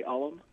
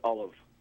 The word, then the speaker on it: Ollam: You're pronouncing it traditionally in the sense that most people do say Alam, but it's actually pronounced Olive. – Olive with a V? – Almost with a V sound, yes. – But it's spelled O-L-L-A-M.